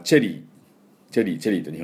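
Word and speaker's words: cherry: で、この、桜、これはですね、まあ、チェリーと日本語で言いますね。で、チェリーブロッサムとかね、日本語、英語では言うんですけども、この辺の発音を今日はですね、ネイティブの音に変えていこうかなと思いますけれども、まあ、チェリーのチェはですね、 CH なんで、これチェじゃなくて、チュなんです。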